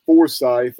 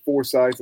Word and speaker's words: Forsyth: North Forsyth. Down there they pronounce it Forsyth instead of Forsyth, like in North Carolina, but those two, Samples and Allen, 6'4", 270 and 6'5", 315.